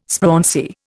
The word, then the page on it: sprauncy: Indian